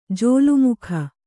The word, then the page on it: ಜೋಲುಮುಖ: jōlu mukha